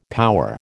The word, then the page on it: power: American English